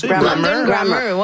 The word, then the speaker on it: Grammar: Grammar.